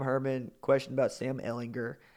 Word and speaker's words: Ehlinger: And Tom Herman went out of his way to say, first of all, it's Sam Ehlinger with a hard G.